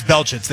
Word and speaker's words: Belchitz: Okay, so it is Belchitz. They pronounced it wrong. – I just pulled up Gabe's tape. – Like, the way it's spelled, you're like, isn't Belchitz? But they said Belch.